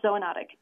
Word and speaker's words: zoonotic: Zoonotic, is that how I pronounce it? – Correct, zoonotic, yes.